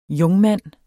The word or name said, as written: jungmand